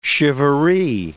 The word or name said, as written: shivaree